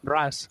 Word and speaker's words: Russ: Bueno, ¿cómo lo, pronuncian ahí en, en, español lo de Rus, Ras? ¿Cómo se pronuncia? ¿Ras? Ras. Ah, sí. Ras. Tal cual. Ah, sí. vale. Tal cual. sí. Es que me preguntaba si sería Rus, decía algo así.